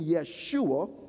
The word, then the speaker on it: Yeshua: And so when we see that Greek name, it comes from the Hebrew name, which when speak in English is called Joshua or the Hebrew pronunciation Yeshua.